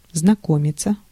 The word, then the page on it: meet: /miːt/